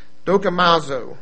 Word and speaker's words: dokimazo: I won't be able to pronounce this probably. Dokimazo. I'll spell it D-O-K-I-M-A-Z-O.